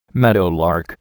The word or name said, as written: meadowlark